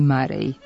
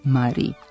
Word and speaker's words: Murray: Sportski komentatori su ga prvo prozvali Marej, ali to se ime nije toliko ustalilo u govoru da ne bi mogao da bude prihvaćen pravilan izgovor Mari.